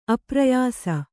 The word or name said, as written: ಅಪ್ರಯಾಸ